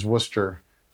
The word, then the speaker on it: Wooster: I went to junior high and high school in the same city town area as her, which is Worcester, W-O-O-S-T-E-R. It's funny, folks say you're from Worcester. You don't sound like you're from Worcester. You know, there's Worcester, Mass, which is spelled and said very differently.